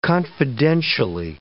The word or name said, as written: confidentially